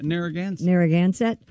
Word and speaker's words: Narragansett: The Narragon, how do you say this? Narragansett Police Department. – Narragansett.